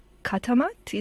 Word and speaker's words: Katamat: And as part of it, you were given a bit of money and commissioned to make a short dance film called Katamat.